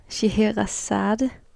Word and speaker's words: Scheherazade: Altså den her titel, Scheherazade, var det sådan, at man udtaler det? Ja, sådan udtaler jeg det i hvert fald.